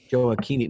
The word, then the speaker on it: Gioacchini: Well, the CH is a hard C. – So, but this one GIOACCHINI. – Yeah, but if it's followed by an H, it's the hard C. Gioacchini.